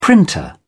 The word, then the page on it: printer: British English